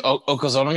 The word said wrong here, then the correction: Yokozuna: going to be there? – Okozono? – Is that how you say his name? – Yokozuna? – Yokozuna, whatever. Is he going to be there?